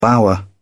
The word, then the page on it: bower: British English